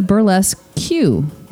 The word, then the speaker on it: Burlesque Q: Oh, you're here to see the burlesque Q. Yes, you pronounce the Q by saying the letter out loud, like Master P or Mickey D's.